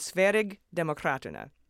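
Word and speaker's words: Sverigedemokraterna: First, I came out of the gate with Sverig Demokraterna, which was close, but you all emailed me saying it was a soft G, which I took to mean a j mm-hmm. sound, like in GIF, not GIF.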